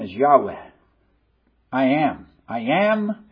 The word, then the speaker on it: Yahweh: And that name that he uses among his covenant people is Yahweh. I think that's probably the best pronunciation of it. Yahweh.